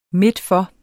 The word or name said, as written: midtfor